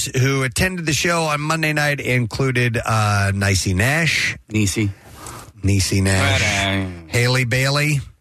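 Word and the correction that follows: Halle: Hallie Bailey.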